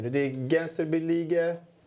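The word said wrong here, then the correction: Gençlerbirliği: Gensler Birligi. Ja, ah, fan vad dåligt uttal ah, exakt. Men du var nära.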